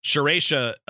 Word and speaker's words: Serratia: Serratia, I was saying it wrong, is a bacteria, apparently, the one that caused the UTIs, which makes sense.